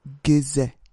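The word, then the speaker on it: Gueuze: But no, this is it's a sour beer, and it's actually a goose, or goose, I think is how you pronounce it. – Goose. – It's a wild fermented, it's another wheat beer, but it's wild fermented. And made in relatively small quantity, so. – Gizze. Like that.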